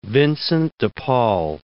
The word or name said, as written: Vincent de Paul